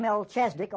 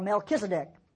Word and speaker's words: Melchizedek: The first thing I want to address with you is the man Melchizedek, or Melchizedek. Depends on where you went to school, how you pronounce it.